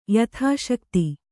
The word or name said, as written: ಯಥಾಶಕ್ತಿ